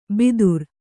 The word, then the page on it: ಭಿದುರ: Kannada